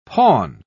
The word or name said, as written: pawn